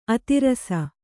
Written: ಅತಿರಸ

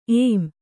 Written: ಏಮ್

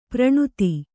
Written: ಪ್ರಣುತಿ

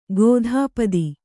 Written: ಕಥಾಪತಿ

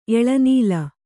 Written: ಎಳೆನೂಲು

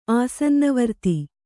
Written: ಆಸನ್ನವರ್ತಿ

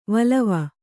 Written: ವಲವ

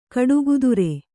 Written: ಗಾಡಿಕತ್ತರಿ

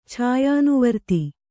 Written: ಛಾಯಾನುವರ್ತಿ

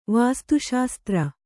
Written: ವಾಸ್ತುಶಾಸ್ತ್ರ

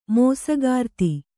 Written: ಮೋಸಗಾರ್ತಿ

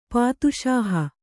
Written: ಪಾದುಶಾಹ

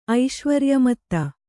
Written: ಐಶ್ವರ್ಯಮತ್ತ

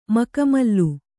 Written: ಮಖಮಲ್ಲು

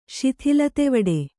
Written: ಶಿಥಿಲತೆವಡೆ

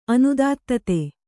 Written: ಅನುದಾತ್ತತೆ

